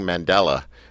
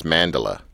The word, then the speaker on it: Mandala: By the way, I keep saying Mandala, like, because that's how we pronounce the band up here, but it's Mandala.